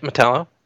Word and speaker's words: Metallo: Metallo, that's how you pronounce his name, isn't it? Metallo? – Yep, Metallo.